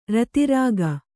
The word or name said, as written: ರತಿರಾಗಿ